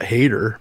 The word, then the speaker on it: Hader: I think his last name is, believe it or not, Hader, but it's spelled H-A-D-E-R maybe.